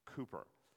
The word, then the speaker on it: Cowper: And 3 years into his ministry, John Newton is introduced to William Cowper, spelled C-O-W-P-E-R, Cowper, pronounced Cooper.